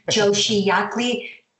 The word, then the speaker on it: Joshi Jäckli: Joshi Jäckli. That one J, as I said, you do say and the other you don't.